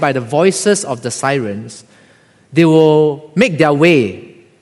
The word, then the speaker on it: Seirenes: It's spelled as S-E-I-R-E-N-E-S, right, Sirens.